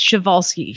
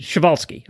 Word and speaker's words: Przewalski: Let me help you pronounce it. It's Przewalski. – Przewalski.